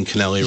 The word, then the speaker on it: Cannelli: Well, whatever happened to Anna Cannelli, and I'm pronouncing Cannelli right. – You are, just like cannoli, huh? – Yeah.